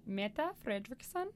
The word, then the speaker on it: Mette Frederiksen: Okay, the Danish prime minister, Mette Frederiksen.